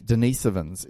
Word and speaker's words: Denisovans: Denisovans is the correct pronunciation. Denisovans. So I keep getting it wrong because I always use the elongated vowel versions, but it's the smaller one.